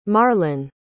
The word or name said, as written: marlyn